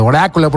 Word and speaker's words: oráculo: Acuérdense de ponerle el acento en la A al oráculo, el oráculo, porque ya vimos que nomás, si no le ponen el acento, pues se va para otro lado y ya no los vemos jamás de los jamases. Pero bueno, ahí está el hashtag, el oráculo del rock, R-A-W-K, eso último, para que usted sepa cómo se escribe, hashtag el oráculo del rock, R-A-W-K.